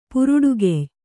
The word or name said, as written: ಪುರುಡುಗೆಯ್